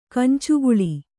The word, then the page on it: ಕಂಚುಗುಳಿ: Kannada